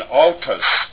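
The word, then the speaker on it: autos (αὐτός): Alpha upsilon is au, as an autos, meaning self.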